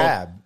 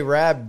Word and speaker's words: Rabb: I say Rabb now. Like, I say Rabb because my grandfather always said that, but and then everyone around here, I think because of the Philly accent.